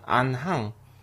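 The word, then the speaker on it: Anhang: 그게 이제 뭐냐면 안항, 독일어로 이렇게, 이거 발음 맞나? 아, 부록이란 뜻이네요. 이거 어떻게 읽냐. 부록, 보유, 뭐, 추신, 뭐, 이런 뜻이네. – 안항. – 이렇게 읽네요.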